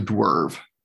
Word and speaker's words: Dewrve: But basically, so it's spelled D-E-W-R-V-E. So Dwerv.